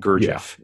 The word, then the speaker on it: Gurdjieff: I think I can do it. Gurdjieff. Gurdjieff. – Yeah, yeah. That's right.